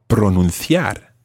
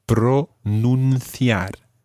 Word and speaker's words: pronunciar: If you're checking whether you've said something correctly, you might want to use the verb pronunciar. – Pronunciar. – Watch that, it's pronunciar. – Pronunciar. – Okay, and in Latin American Spanish, obviously, that would be pronunciar.